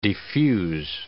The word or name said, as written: defuse